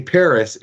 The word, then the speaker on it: Peris: And I say Paris, it's spelled P-E-R-I-S, not to be confused with the Paris of the 2024 Olympic Games.